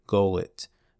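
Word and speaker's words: Golyt: And a third I wanted to highlight, I feel like I'm going to butcher their name here, but it's G-O-L-Y-T, Gollit. I'm sorry, Gollit is the drop. The artist is Flins, P-H-L-I-N-S.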